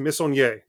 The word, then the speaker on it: Missonnier: So, Pumpkin Jack is a very Halloween-themed 3D platformer coming to us from Head Up Games and developer Nicolas, I believe it's pronounced, Missonnier. – Yes, yes. French indie developers solo developed this game.